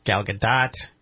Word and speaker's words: Gadot: I always say Gal Gadot. I know some people say it's Gal Gadot. – I say Gadot.